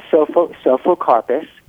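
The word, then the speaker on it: Psophocarpus: So again, this is Asian winged bean, Physocarpus tetragonolobus? – Uh-huh. Sophocarpus. It's okay. – A silent P, like the word psalm, you know, P-S-A-L-M, Sophocarpus tetragonolobus.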